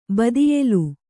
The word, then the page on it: ಬದಿಯೆಲು: kannada